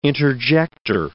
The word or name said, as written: interjector